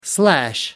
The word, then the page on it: slash: American English